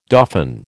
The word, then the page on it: dauphin: American English